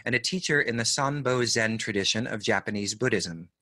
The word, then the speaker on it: Sanbo: Actually, am I pronouncing that correctly? – Sanbo. – Sanbo. Sanbo Zen.